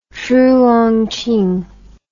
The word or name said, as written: truong chinh